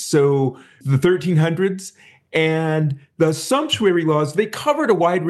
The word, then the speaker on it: sumptuary: and there's something called sumptuary law. Am I pronouncing that right? – Yes, you are.